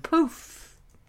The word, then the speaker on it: pouffe: It's spelled P-O-U-F-F-E, and it means something that's puffed out. It's a poof.